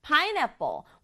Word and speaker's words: pineapple: Pineapple, pineapple. 우리말로도 pineapple인데요. 우리말은 pineapple, 네 박자지만 영어는 pineapple, 세 박자예요.